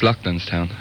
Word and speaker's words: Loughlinstown: That's in Lushvini, I think the name of that. Excuse my pronunciation on that. County Dublin. Lughshiny. Can you elaborate on that at all? – That's Loughlinstown, I think.